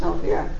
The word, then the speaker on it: Elvira: My birth mother's name was Elvira, but in Italian it is pronounced Elvira.